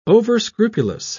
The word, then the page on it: overscrupulous: British English